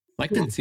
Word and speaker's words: lectins: Lectins, yeah.